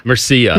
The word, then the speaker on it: Murcia: I'm sure I'm butchering that. Murcia? Mercia? – Mercia.